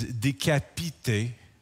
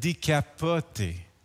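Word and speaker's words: décapité: Well, later on, somebody explained to me, well, you didn't pronounce the word right. Because the word for beheaded is decapité, but you said decapote. Exactly. Decapité is the word for beheaded, and decapote is the word for a convertible.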